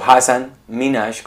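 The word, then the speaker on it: Hasan Minhaj: la gente que no se sintonizó al live que hicimos el viernes, hay un comediante muy famoso aquí, Hassan Minaj, o Hassan Minaj, como le dicen los, la gente que eh, sabe pronunciar su nombre bien.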